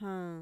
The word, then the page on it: jaan: Mixtec